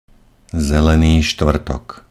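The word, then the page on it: zeleny stvrtok: Slovak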